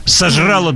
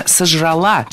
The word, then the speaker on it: сожрала: Сожрала, неверно, правильно, сожрала. Ударение опять же переносим в конец слова. Поняла, сожрала. Мы имеем ударное окончание в этих и многих других проблемных глаголах прошедшего времени женского рода. И это уже тенденция. В большинстве подобных слов ударение падает именно на окончание.